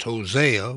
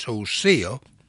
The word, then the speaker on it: Hosea: Sometimes that's pronounced Hosea, but actually it's Hosea.